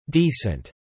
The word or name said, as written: decent